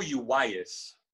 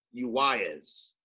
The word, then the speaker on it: Uwais: And this guy, Iko Uwais, am I pronouncing it right? – It's Iko Uwais. – Uwais. – Yes.